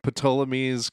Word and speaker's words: Ptolemy: Ptolemy's Cosmographia. He's like, I know you don't want anything to happen to this. – It's pronounced Ptolemy. The P is silent.